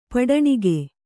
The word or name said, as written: ಪಟ್ಟಣಿಗೆ